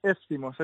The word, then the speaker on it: Éftimos: porque tú ya tienes tu marca Étimos, ¿no? Éftimos, ¿se escribe? – Éftimos, eso es, justo.